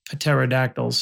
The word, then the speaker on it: pterodactyls: What you said is that our point of view is that we're staring up because they're birds flying, or in this case, pterodactyls. – Pterodactyls. Yeah. – Oh, I'm sorry. The P is silent.